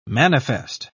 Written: manifest

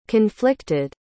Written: conflicted

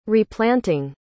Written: replanting

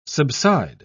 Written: subside

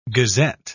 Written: gazette

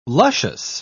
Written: luscious

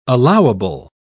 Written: allowable

